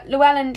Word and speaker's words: Llewellyn: Llewellyn should actually be pronounced with the weird Welsh double L thing, but I can't do it.